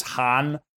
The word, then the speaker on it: Han: And the way I've heard it always pronounced is Han. And so, you could pronounce it multiple ways depending on when it's Mandarin or such, but just to disambiguate from Hun, like Attila the Hun. – Oh, thank you. Yeah. Right. – So the H-U-N.